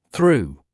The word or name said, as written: through